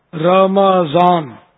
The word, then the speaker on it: رمضان: رمضان تلفظ غلط ہے, رمضان میم پر زبر ہے, اور امام مہندی نہیں ہے.